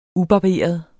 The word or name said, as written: UBARBERET